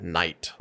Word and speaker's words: knight: For example, a simple word like knight, like a knight in shining armor, that K is silent, right?